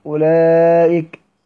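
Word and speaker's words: أولئك: الواو في اولئك، يعني الواو اللي بين الهمزة واللام دي مش لا تنطق اصلا، لا تنطق.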